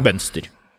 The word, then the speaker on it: Mønster: I Norge O med strek innom, det Ø, uttales slik, ja. Mønster.